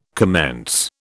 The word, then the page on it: commence: British English